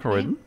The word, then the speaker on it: Croydon: Or is that how you say it? Croydon? – Yeah. Croydon, yeah. Correct.